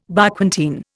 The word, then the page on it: Barquentine: Indian